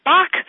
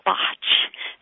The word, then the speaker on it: Bach: In the United States, we typically call them Bach flowers, like the composer Bach. However, Dr. Edward Bach was English, and they pronounce it Botch. So you guys across the pond, so to speak, call it Botch.